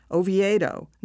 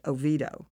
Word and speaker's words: Oviedo: Richard Addox and Donna Neely's Oviedo Biography of a Town assures us that Ahlin pronounced the name Oviedo, not the Americanized Oviedo.